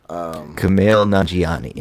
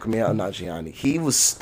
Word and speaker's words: Kumail Nanjiani: Kumail Nanjiani. – There you go, Kumail Nanjiani.